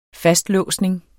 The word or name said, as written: fastlåsning